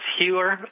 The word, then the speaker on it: Heuer: Before I go there, I just want to say my name is spelled H-E-U-E-R, and it's pronounced Hewer.